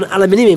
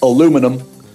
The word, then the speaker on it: aluminum: And but he's imposed, I think it's 25% on steel imports, 10% on aluminium imports. Now it turns out there are exemptions. – Sorry, you're pronouncing it wrong there. It's aluminum.